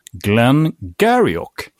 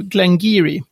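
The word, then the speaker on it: Glen Garioch: Det stavas Glengarioch med CH på slutet. Det uttalas, håll i er nu, Glengeary.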